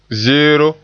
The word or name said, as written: zeero